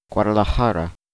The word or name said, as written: Guadalajara